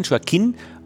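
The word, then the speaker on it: Joaquín: Joaquin, Joaquin Orellana. – Joaquin, also, Joaquin, Joaquin. Also der Akzent muss man es betonen, Joaquin, Ore, Joaquin Orellana.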